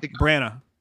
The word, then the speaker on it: Branagh: The other one that I would pick, and he's already under the MCU umbrella, is Kenneth Branagh. I don't know if I'm saying it right. – Branagh.